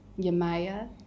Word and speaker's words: Yemaya: And just to paint people a picture, we're sitting in a dark room because we decided to cover everything for Yemaya.